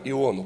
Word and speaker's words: Иону: Я специально сделал ударение, пророка Иону, проповедника.